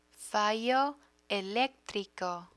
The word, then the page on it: fallo electrico: Spanish